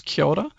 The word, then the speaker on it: kia ora: It sort of all runs together. It's kia ora. – Kia ora. – It's spelled K-I-A-O-R-A, but you kind of run it all together.